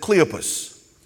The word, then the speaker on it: Cleophas: And this is Mary, the sister, the wife of Cleophas. And some people pronounce it Cleopas.